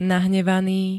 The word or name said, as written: nahnevaný